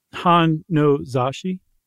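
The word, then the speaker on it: Han no Zashi (本の雑誌): And she sent a letter to a magazine in, 1985 in the issue of Han no Zashi. Is that right? – Close enough. – Well, how would you say it? – Han no Zashi. – Isn't that what I said? – Yeah.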